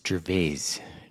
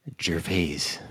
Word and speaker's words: Gervais: I don't think you pronounce the I. It's Gervais. – Say that again. – Gervais.